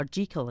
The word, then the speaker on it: Jekyll: Robert Louis Stevenson's Strange Case of Dr. Jekyll and Mr. Hyde, or Jekyll as he pronounced it.